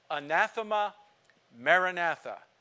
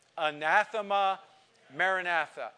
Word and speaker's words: anathema maranatha: So if you were to read those two words in the Greek, you couldn't read it, but it would be pronounced anathema maranatha. Say that. Anathema maranatha.